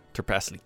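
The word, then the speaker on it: Trpaslík: Uh, it's spelled T R P A S L I with an accent K. – Tarpasalik? Yeah, Tarpasalik. D- is there a word for dragonborn? – Probably not.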